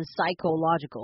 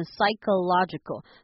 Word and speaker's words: psychological: 那这边的话我们不会念成 psychological，我们念成 psychological，所以中间那个 CHO 是可可可的音，没有其他的音了，就是 psychological 就可以了。